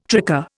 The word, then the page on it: tricar: Indian